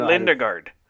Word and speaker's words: Lundegaard: And a guy named Jerry Lundegaard, who's a sales manager for a car dealership. – You gotta say it with the Minnesota. You gotta say it like we say it, Jim. It's Jerry Lindegaard.